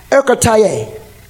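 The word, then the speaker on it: enkrateia: Ekratia. Ekratia is pronounced ekratia, and it is a Greek term, means self-control.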